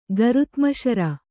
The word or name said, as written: ಗರುತ್ಮಶರ